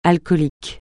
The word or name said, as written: alcoolique